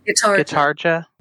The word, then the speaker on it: Gitarja: She's wedged in between Cleopatra and Gitjura? Is that how you say her name? – Gitarja. – Gitarja, yeah.